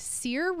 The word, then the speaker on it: SEER: somebody's asking about how important a SEER rating is. Is it S-E-E-R, Todd? Am I saying it correctly? – Yeah. A SEER rating is a seasonal energy efficiency rating.